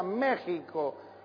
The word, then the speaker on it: México: Se escribe México, se pronuncia México.